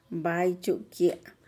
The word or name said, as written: bai-chukye'